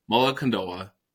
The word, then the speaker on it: Mullokandova: Mullokandova, did I say that correctly? – That is perfect.